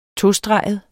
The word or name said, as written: tostreget